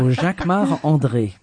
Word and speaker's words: Jacquemart-André: Jacquemart-André. Jacques Arnaud, you say it like André. A Frenchman. Jacques of course, because I'm French. So Jacquemart André.